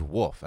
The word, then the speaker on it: wolf: Do you not pronounce Wolf? – Wolf? – You just... – It was a little wolfy. – you just barked like a dog. – Woof. Oh, woof. – Yeah, wolf. The L is, you're breezing past that L.